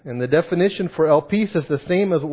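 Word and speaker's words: elpis (ἐλπίς): And the Greek word used here is elpis. That's how you pronounce it, elpis.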